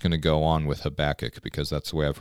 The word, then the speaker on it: Habakkuk: We're going to be here in one of the most fun parts of the Bible to pronounce, which is Habakkuk. Okay, Habakkuk.